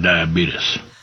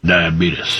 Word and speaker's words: diabetes: Wilford, I think you're saying it wrong. – Diabetes. – No, Wilford, it's diabetes. It's diabetes. – Diabetes. – Yeah, okay. It's an extra vowel in there.